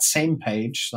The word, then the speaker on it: SMPAG: The acronym is SMPAG. We pronounce that same page. That's just nicer to pronounce.